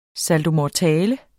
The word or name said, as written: saltomortale